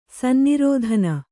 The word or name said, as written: ಸನ್ನಿರೋಧನ